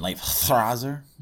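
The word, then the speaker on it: Leifthrasir: I'm still playing more Odin Sphere Lifthrazer. – You're getting better at pronouncing that. – I mean, Lifthrazer? – That's better. Joey, do you approve? – Yes, okay. That sounded better.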